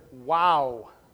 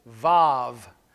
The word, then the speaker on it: Waw: And the letter of the Hebrew alphabet that we are in this morning is the letter WOW, W-A-W, or you could also pronounce it VAV, because sometimes the Hebrew letter sounds like a V, sometimes it sounds like a W.